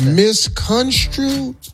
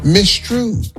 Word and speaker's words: misconstrued: It's misconstrued? – Yes, sir. – Yeah. – What were you going to say? – Mistrued. – Who is that? – You need that middle syllable.